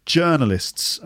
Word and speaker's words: journalists: Journalists, okay? And the point here is the vowel sound in the first syllable. Journalists. So not journalists, okay? So it's not or, like tea or coffee, but like her.